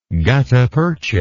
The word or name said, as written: guttapercha